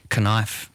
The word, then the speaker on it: knife: Knife. – Well, that's fair enough, Why put a K in there? – I don't know.